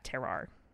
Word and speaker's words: terroir: terroir. I don't know how to pronounce terroir. – That word. – Terroir. It's weird in French.